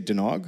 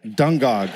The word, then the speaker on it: Dungog: Are you guys going to get rid of the G and say Dunog? What do you say? Fuck that. Dungog. Okay, Dungog. To deliver a baby.